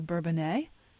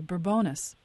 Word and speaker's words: Bourbonnais: And also, the caller mentioned Kankakee, and near Kankakee, there's a community that the people that live there are divided on how to pronounce their town's name. – Right. – Some will say Bourbonnais, and some will say Bourbonus.